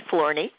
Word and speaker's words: Florney: Now, I hope that's right, because I know a gal named Marcy Florney, and she pronounces her name that way, but it looks like Flower Noy.